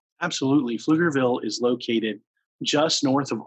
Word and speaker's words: Pflugerville: And you can find us online at wayoflifepf, Pflugerville.